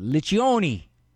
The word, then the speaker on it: Liccioni: I got this from the New Oxford Review, supposedly said by a gentleman named Michael, and I'm going to be Italian, so I'm going to pronounce it Liccioni. CC is ch.